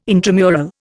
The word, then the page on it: intramural: Indian